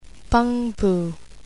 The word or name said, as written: Peng-pu